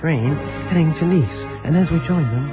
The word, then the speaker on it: Nice: The city's called Nice. It's spelled the same way as the word nice, but they pronounce it Nice over here.